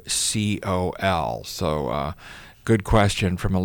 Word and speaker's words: Sambucol: I pronounced it Sambucol. – Sambucol. – S-A-M-B-U-C-O-L.